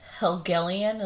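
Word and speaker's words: Helgelein: How do you say his last name? – Helgelian is what I would say.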